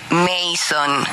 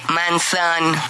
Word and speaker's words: Manson: Además, no se dice Mason, se dice Manson.